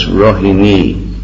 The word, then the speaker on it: Rohini: It may be noted here that Rohini is not Rohini. – Just for your information, it's Rohini, not Rohini. – I know, we know Rohini Sutta, but it's Rohini. Long I on the end.